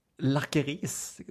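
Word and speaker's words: lakris: Lakeris? Du uttalar alltså, inte lakeris, men lakeris? – Lakeris, ja. Lakeris.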